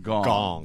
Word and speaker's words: Kang: It's spelled K-A-N-G, but it's pronounced Gong.